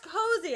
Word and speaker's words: Cosi: Like, it's pronounced cozy, and they spell it COSI.